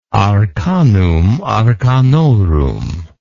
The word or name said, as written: arcanum arcanorum